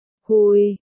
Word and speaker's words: Tiết Bạch Quy: Thật ra thì Tiết Bạch Quỷ nguyên danh cũng không gọi là Bạch Quỷ, mà gọi là Tiết Bạch Quy, cả hai chữ đều đọc là Quỳ,